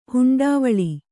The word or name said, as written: ಹುಂಡಾವಳಿ